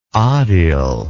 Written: odyl

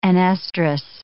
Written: anestrus

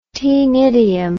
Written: taenidium

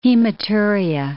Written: hematuria

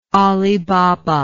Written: Ali Baba

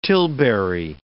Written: tilbury

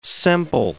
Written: semple

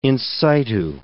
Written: in situ

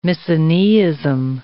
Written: misoneism

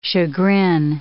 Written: chagrin

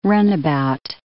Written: runabout

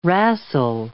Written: rassle

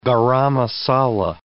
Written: garam masala

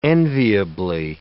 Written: enviably

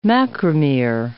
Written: macromere

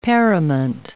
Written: parament

